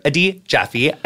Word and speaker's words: Adi Jaffe: This is Jonathan Van Ness, and this week I'm very excited to welcome Dr. Adit Jaffe.